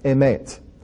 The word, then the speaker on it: emet: Now, reading from right to left, that word that you see there that has the three letters, aleph, mem, tav, again, reading right to left, pronounced emet. That is the word truth.